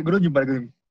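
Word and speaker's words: Groningen: que es el el Groninger, creo que se pronuncia así, ¿no, Matador? – Eh, sí, Groninger.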